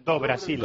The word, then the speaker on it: do Brasil: ¿Es Do o Du? ¿Cómo se pronuncia? – Do Brasil. – Do Brasil. Sí. – Bye, non bye. – Vale. – Bueno, en el norte de Brasil es Du, pero en el sur es Do.